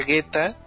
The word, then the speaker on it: geta: Por aquí, mira, Medina nos pone que se pronuncia Geta y se escribe con unos kanjis, que los cuales no voy a describir porque soy malo para eso.